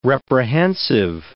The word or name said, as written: reprehensive